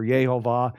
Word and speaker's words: Yehovah: And it is Jehovah, well, we would say Jehovah in English or Yehovah in Hebrew. So we have here L-O-R-D, God says, my name is Jehovah, translated L-O-R-D in verse 2, but actually not translated at all, but just given God's name Jehovah in verse 3.